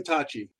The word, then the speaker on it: tachi: This is a Tachi. Is that right? Am I pronouncing that right? – Yeah, it's a golden Tachi.